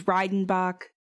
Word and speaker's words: Redenbach: I think I said Redenbach before. I'm going by German pronunciation and saying this is Redenbach.